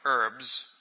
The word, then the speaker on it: herbs: It says, Better is a dinner of herbs, and it's not herbs, Wally, who always hassles me about silent H's. Better is a dinner of herbs where love is than a stalled ox and hatred therewith.